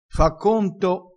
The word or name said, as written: fa conto